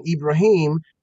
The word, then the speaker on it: Ibrahim: So the Hebrew pronunciation is Abraham. He took the Arabic pronunciation, Ibrahim, and took that as a first name instead of Lawrence.